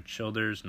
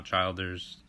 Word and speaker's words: Childers: So for my English project, I decided to look at the artist Tyler Childers, not Childers. Believe me, I've pronounced it wrong 1,000 times trying to make this podcast.